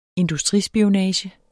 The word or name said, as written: industrispionage